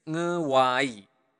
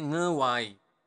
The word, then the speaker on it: ngewai: Every day is ngewai. Okay, repeat up to me. ng ngewai. Okay, ngewai.